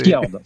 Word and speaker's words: Fjord: Fjord.